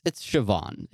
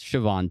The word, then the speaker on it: Siobhan: I pronounced Soyabon or however I said it, but it's Siobhan. It's Siobhan Thompson.